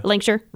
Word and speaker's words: Lancashire: Just say it really fast. – Lancashire. – Yeah, right. And take out half the vowels, half the... – Lancashire.